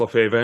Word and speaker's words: Lefebvre: Most people can't spell or pronounce Lefebvre. I only do that when I'm doing the author helping stuff because I'm known in the industry under that long, hard to pronounce French name.